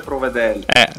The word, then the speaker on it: Provedel: Peraltro, Ivan, Provedel o Provedel? Perché insomma, è importante anche... – Vero, piazzare l'accento è fondamentale, quindi sentiamo dal diretto interessato. – Allora, dalle mie parti si dice Provedel.